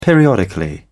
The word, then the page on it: periodically: British English